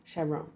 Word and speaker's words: Chiron: And I'm pronouncing that right, Chiron. Chiron. – Yeah, Chiron.